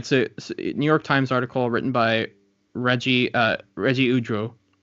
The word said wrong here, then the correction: Ugwu: I'm sorry, I pronounced that wrong, but Ugu.